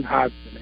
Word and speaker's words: Hodgson: The G is actually silent, so it's Brian Hodgson. H-O, you say it like H-O-D-S-O-N.